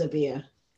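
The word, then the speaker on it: Obeah: Well, so I love how you talk about how you have how you practice a mixture of British witchcraft and Obi. You said did I pronounce it incorrectly? – Well, different places pronounce it in different ways. So my family come from Jamaica, so I've always pronounced it as Obia.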